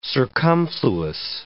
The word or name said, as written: circumfluous